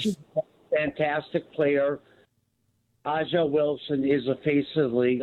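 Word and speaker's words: A'ja: Yeah, it's not Angel Wilson. It's Aja.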